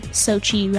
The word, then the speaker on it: Sochi: And I was talking about the fashion at the opening ceremony for the 2014 Winter Olympics held in Sochi, Russia.